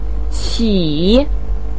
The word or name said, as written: qi3